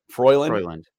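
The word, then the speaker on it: Frelund: Cynthia, I don't know how to pronounce her name. I've never heard of it. – Froyland. – Okay. Froyland put out a 4 win-win trades that would make sense.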